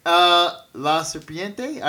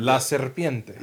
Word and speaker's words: La Serpiente: La Serpiente? I didn't say that right. – La Serpiente.